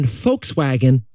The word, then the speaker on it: Volkswagen: As in Volkswagen with an F. – It's a V pronounced like an F.